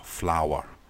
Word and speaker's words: flower: And the OU sound in flower is ow, ow, flower, flower, flower.